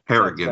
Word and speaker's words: Harrigan: Did I say that right? – I think Mr. Harrigan's. Harrigan's. That's about right.